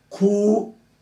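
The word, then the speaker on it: kuneo (γυνή): In verse 35, the Greek word for woman again is once again kuneo, G-U-N-E. And it's pronounced this way, G-O-O-N-A-Y, kuneo, or nay, whatever.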